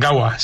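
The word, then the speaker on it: Gaua: ¿Lo he dicho bien? ¿Gawa? ¿Wow? – Sí, bien, Gawa, sí.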